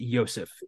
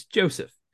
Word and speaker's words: Joseph: His brother does not pronounce it Yosef. It's Joseph.